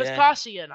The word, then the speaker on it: Vespasian: Vespian? Yeah. Vespasian. I feel like I am... – Vespasian? – That's how it's spelled. I keep calling him Vespian, though.